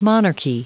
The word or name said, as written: monarchy